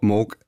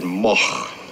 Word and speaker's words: Moog: It's originally a Dutch name, and in, in Holland you would pronounce it Moch. Now that's a little bit too much to expect people to do here. Uh, in Germany, uh, it's a fairly common name, and there it's pronounced Moog.